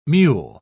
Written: mewl

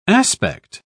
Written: aspect

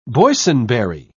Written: boysenberry

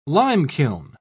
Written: limekiln

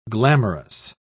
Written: glamorous

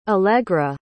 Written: alegra

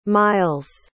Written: Myles

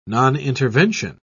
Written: nonintervention

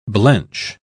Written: blench

